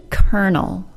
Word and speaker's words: colonel: H-A-L-F. Folk. F-O-L-K, and yolk, Y-O-L-K, as in the yellow part of an egg. The word kernel, spelled C-O-L-O-N-E-L, has one of the least intuitive pronunciations we have in modern English, which makes it kind of a fun word in its own right. The O-L-O part of the word is simply pronounced as schwa plus R, giving us kernel.